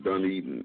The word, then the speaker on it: Dunedin: What is that, Dunner Brewery? And that's November the 9th, correct? – Yeah, Dunedin. It's spelled D-U-N-E-D-I-N. Dunedin Brewery. – Okay. – In Dunedin Brewery in Dunedin, Florida.